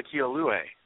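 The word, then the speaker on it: Ka'aihue: I thought we were going to talk about Kila Kialue. – That's the big first base signing for Syracuse, yes. And thank you for pronouncing his name.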